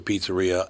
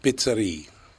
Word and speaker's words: pizzeria: He pronounced it pizzeria. – Pizzeria. Because I thought when I was a kid, when I saw that, I thought he was saying pits, like P-I-T-T. – Right. But pizzeria is actually how you would pronounce it, but pizza sounds terrible.